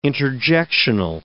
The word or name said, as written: interjectional